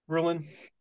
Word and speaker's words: Rulon: Did I pronounce that right? Rulon? – Yep, you did. Absolutely, Rulon. Okay, and then a lot of people mispronounce it, but that is the proper one.